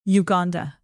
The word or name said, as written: uganda